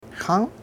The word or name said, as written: xung'